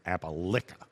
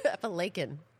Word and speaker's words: Appalachian: And it had to do with the Appalachian Mountains and you saying that I was saying it wrong and it's Appalica or something like that. – Appalachian. – That's just not right. It sounds weird.